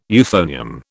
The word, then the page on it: euphonium: British English